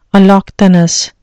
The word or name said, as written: allochthonous